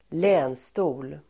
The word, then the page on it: länstol: [²l'ä:nsto:l]